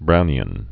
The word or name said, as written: Brownian motion